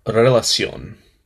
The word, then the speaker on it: Relación: Titled Relación, sorry, I butchered that. Relación, by Sech, Daddy Yankee, J Balvin, Rosalía, and Farruko.